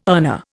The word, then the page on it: earner: Indian